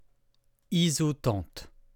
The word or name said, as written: isotente